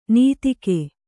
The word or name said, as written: ನೀತಿಕೆ